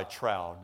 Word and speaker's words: trow: Now, I will tell you that I've always pronounced it trow my whole life. But for this particular sermon, I looked it up in Merriam-Webster's self-pronouncing dictionary. I'm talking, you push the button and the voice comes on. And it says, tro.